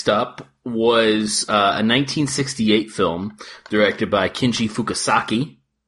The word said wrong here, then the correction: Fukasaku: or Fukasaku, I think is how you say it. Fukasaku.